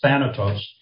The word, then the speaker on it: Thanatos: It's the one word that I know how to pronounce, Thanatos.